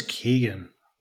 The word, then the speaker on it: Keoghan: So we're going to have Pedro Pascal, Denzel Washington, and I'm going to mess up this name, but Barry Keoghan, is that how you say it? – I thought it was Keegan. – Barry Keegan, that makes sense.